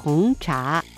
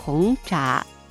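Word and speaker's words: 红茶: 発音は紅茶、紅茶というふうになります。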